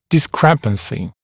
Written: discrepancy

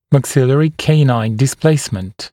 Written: maxillary canine displacement

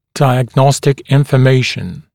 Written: diagnostic information

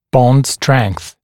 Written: bond strength

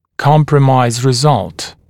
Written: compromise result

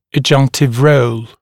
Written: adjunctive role

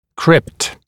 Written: crypt

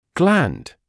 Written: gland

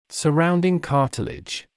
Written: surrounding cartilage

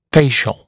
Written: facial

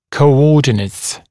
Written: coordinates

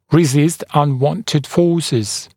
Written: resist unwanted forces

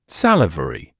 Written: salivary

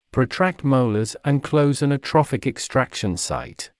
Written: protract molars and close an atrophic extraction site

